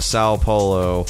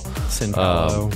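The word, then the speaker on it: São Paulo: So they're in Sao Paulo. That's not how you pronounce it at all. – Sao Paulo.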